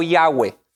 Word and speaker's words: Yahweh: Y la mayoría de los expertos bíblicos nos aconsejan como cristianos eh, pronunciar el nombre de Dios como Yahweh. Entonces, Yahweh sería el nombre más correcto.